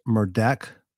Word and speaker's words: Murdaugh: It's pronounced Murdaugh, because if you'll watch the beginning of this video, that's how he pronounced his own name.